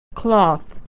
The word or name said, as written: cloth